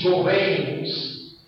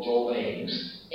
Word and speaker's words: jovens: Aquí jovens, pronunciado agudo, jovens, es un sustantivo colectivo,